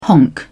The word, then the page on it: honk: British English